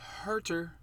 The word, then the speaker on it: Huerter: Number what 10987 on the list, Kevin Huerter.